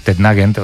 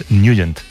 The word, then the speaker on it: Nugent: Hombre, siempre lo hemos llamado en el barrio Tednagent o Tednagent. – Claro, yo era de Tednagent. – Pero la pronunciación correcta es Nugent. – Te fuiste a Washington y descubriste que era Nugent.